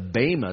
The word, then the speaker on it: bema: It's the judgment seat of Christ, referenced in Greek as the bema. The bema is how should be pronounced.